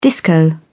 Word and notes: disco: British English

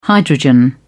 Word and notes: hydrogen: British English

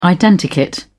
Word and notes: identikit: British English

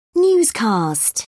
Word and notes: newscast: British English

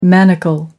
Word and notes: manacle: American English

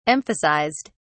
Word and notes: emphasized: British English

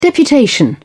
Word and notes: deputation: British English

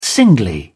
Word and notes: singly: British English

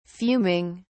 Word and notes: fuming: British English

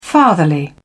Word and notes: fatherly: British English

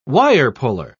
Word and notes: wirepuller: British English

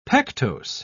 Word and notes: pectose: British English

